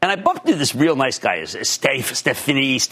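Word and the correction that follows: Stéphane Bancel: I wasn't sure how to pronounce his name, Stephane Bancel, okay? Stephane Bancel.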